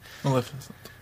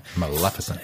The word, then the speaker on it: Maleficent: And the big dragon fight scene with Maleficent. – I say that wrong every time. – Maleficent.